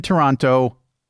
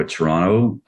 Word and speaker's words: Toronto: It depends. Basically, we drop the last T. So you will sort of do a bit more of a Toronto, where you're not adding sort of the last T into the mix.